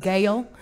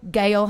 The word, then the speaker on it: Gal: In fact, Gail, because in the South people have a really hard time saying gal without the I, Gal Holliday. Just can't get it around. So gal is immediately, even without the I, gal is pronounced Gail. Gail Holliday.